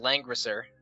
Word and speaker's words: Langrisser: I don't have it up, but it's pronounced Langrisser.